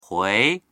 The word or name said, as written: hui2